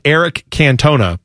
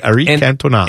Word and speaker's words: Cantona: That's just it looks like Eric Cantona, but that's not how you say his name. He's French, so you say Eric Cantona.